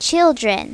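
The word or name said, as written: children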